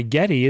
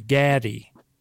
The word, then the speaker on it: Geddy: And the reason he went by Geddy is because his mom was from Eastern Europe, and that's how she pronounced Gary. Gaddy.